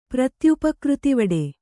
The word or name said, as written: ಪ್ರತ್ಯುಪಕೃತಿವಡೆ